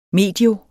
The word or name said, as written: medio